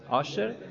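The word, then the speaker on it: ошер (עשר): Если ты хочешь стать богатым, Ошер, значит, давай массер. Это, это слово э, асер читается только как ошер.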